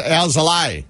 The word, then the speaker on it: Alzolay: And he gave us a scouting report on Alzola, whatever. All right? – Now it's Alzolay. – Thank you. – That's how you say it.